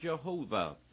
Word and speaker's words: Jehovah: you'll find out that it is Jehovah, J-H-V-H, with the vowels included. We pronounce it Jehovah.